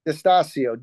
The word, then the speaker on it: Distasio: Tell us your last name so we say it right. – Distasio. D-I-S-T-A-S-I-O.